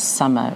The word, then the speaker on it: Somer: One of my characters, a female detective, her surname is Summer, which is spelled S-O-M-E-R, which is an anagram of Morse, which is appropriate for Morse being a crossword guy.